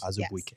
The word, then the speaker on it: Azubuike: But Woj tweeted out that free agent center Udoka Azubuike. – Mm-hmm. – Azubuike. – Mm-hmm. So close. – Azubuike. – Isn't that what we decided that he had said? – Mm-hmm. That's how he pronounces it.